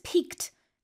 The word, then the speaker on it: pique: So this piqued his curiosity. – Yeah, this is a good word here, pique, and it's spelled P-I-Q-U-E.